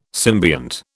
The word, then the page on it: symbiont: British English